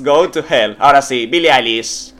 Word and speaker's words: Billie Eilish: Ahora sí lo he dicho bien. Es Billie Eilish.